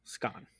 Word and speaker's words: scone: Karen pronounces it like an O, with an O like in gone. So, scone.